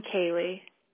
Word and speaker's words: Cailey: I'm Kaylee. – Kaylee with a Y. – Yeah, it's spelled CAILEY. – Kaylee. Okay. – Yeah, like Bailey with a C.